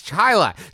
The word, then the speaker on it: Chili: This is from Too Much Chili in Chili, pronounced Chilei, New York. – It's Chila. Too Much Chili in Chila, New York.